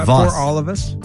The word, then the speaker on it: Paralovas: Almost like paralysis. But Paralovas. – Paralovas.